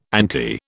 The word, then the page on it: anti: British English